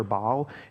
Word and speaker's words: Baal: It's B-A-A-L, okay? And so a lot of times people just say, you know, Baal or Baal. It's Baal is how you pronounce that in Hebrew.